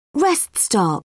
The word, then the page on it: rest stop: British English